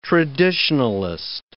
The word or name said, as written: traditionalist